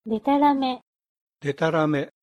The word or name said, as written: detarame